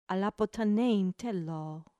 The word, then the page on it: ἀποθανεῖν θέλω: ap ʌ t h an ɛ i ː n t h ɛ l ɔː; apothanein thélô